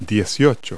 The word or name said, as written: eighteen